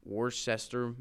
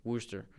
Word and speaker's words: Worcester: and Worcester, Massachusetts. – Worcester.